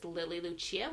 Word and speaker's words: Lily Lucia: My name is Lily Lucia, which is spelled LILYLUCIA, as well as my company, Illuminating Shakti.